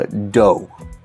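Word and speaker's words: dō: Uh, do. Okay, let's just go with that. D O. Uh, in Japanese, it's pronounced do, so it's way.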